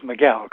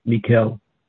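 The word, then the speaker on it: Miquel: My understanding was that it was Miguel. Could somebody clear that up, please? – It's spelled with a Q. He's Catalan. His name is, it's not a Spanish name. It's M-I-Q-U-E-L, Mikkel.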